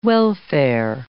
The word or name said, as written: welfare